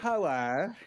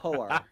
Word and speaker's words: whore: It's whoer. You have to pronounce the W. Whoer.